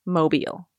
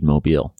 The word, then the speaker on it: Mobile: It's Mobile. – It is. It is indeed Mobile.